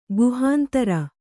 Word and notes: ಗುಹಾಂತರ: kannada

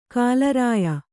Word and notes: ಕಾಲರಾಯ: Kannada

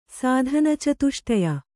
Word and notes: ಸಾಧನಚತುಷ್ಟಯ: sādhana catuṣṭaya

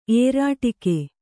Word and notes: ಏರಾಟಿಕೆ: Kannada

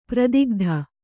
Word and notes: ಪ್ರೋದ್ಗತ: prōdgata

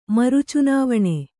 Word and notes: ಮರುಚುನಾವಣೆ: maru cunāvaṇe

